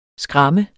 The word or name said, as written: SKRAMMEDE